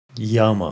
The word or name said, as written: Yama